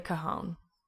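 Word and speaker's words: cajón: Definitely a guitar, acoustic guitar, and a drum, like a cajon. It's the one you sit on. And it's got like a snare-y sound. It's cajon? – Cajon. Yeah, it's spelled like Cajun, almost.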